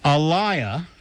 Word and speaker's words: Elia: Well, in fact, Elia, rhymes with papaya,